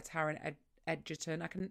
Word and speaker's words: Taron Egerton: And the audiobook is narrated by Taron Egerton. That's how you say his name, right? – I think so.